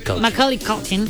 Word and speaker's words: Macaulay Culkin: Macaulay Culkin, ¿así se dice? – Macaulay Culkin.